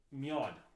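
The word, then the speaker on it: Mjød: It says on the bottle, D-A-N-S-K, which I think is pronounced Dansk. – Okay. – And then Mjod, which I believe is the M-J-O-D, which is a lot like Mjolnir for people who like Thor. – Yeah. – The Avengers. You got Mjolnir, you got Mjod. That's how it's pronounced.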